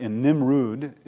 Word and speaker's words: Nimrud: If you don't mind turning that light off just for a moment, this is a relief from the palace in Nimrud, it's pronounced.